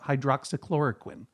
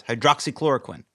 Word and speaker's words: hydroxychloroquine: Your pronunciation of hydroxychloroquine. – Well. – It's hard. – It's hard. It is hard. And I appreciate that. I appreciate that. It took two tries. Hydroxychloroquine.